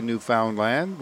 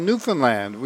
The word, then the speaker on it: Newfoundland: But he doesn't say Newfoundland, which is not how we pronounce it. He says A Place Called Newfoundland, which is how we pronounce it.